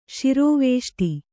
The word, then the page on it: ಶ್ರವಿಷ್ಠೆ: śraviṣṭhe